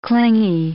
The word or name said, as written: clingy